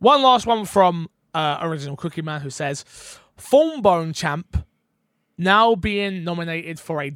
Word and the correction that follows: Trombone Champ: Is that, have I said that right? – Dice. Trombone Champ.